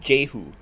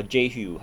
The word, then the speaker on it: Jehu: And his name is Jehu, or Jehu, however you want to pronounce it, J-E-H-U, Jehu.